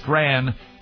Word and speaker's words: Strachan: It's spelled Strachan. That's right. Strachan, Strand.